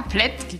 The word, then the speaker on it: Plättki: Ähm, und was ist denn das Blättki Lexikon. Habe ich das richtig ausgesprochen? Ja, Plättki, genau.